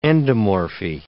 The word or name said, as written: endomorphy